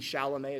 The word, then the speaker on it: Chalamet: A great example of this is in the Netflix film, The King, about King Hal, starring, I think, Timothy Chalamet is how you pronounce his name.